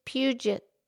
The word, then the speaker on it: Puget: No, Mildred, you said it wrong. There's no N. It's just Puget.